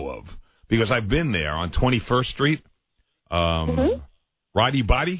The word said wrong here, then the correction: Roti Boti: is that how it's pronounced? – Oh, Roti Boti.